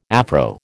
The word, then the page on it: Afro: American English